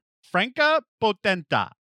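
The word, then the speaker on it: Franka Potente: Franca Potenta. – That's better.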